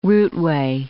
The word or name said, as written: routeway